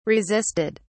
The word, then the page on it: resisted: British English